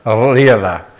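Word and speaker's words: Lera: It's L-E-R-A. If you wanted to pronounce it right, you'd roll the R's. Lira. As far as that's how, because that's how they do with all their R's.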